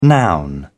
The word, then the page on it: noun: British English